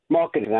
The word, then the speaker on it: Markkanen: Markkanen.